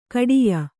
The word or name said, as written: ಖೇಡ್ಯ